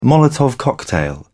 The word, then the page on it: molotov cocktail: British English